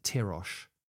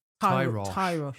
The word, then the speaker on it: Tyrosh: And Tyrosh. – Tyrosh.